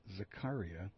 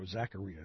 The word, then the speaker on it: Zakaria: Columnist for Reed Zakaria, or Zakaria is how you say his name, I'm sorry, Zakaria, suspended by CNN for plagiarism.